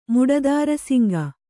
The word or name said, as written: ಮುಡದಾರಸಿಂಗ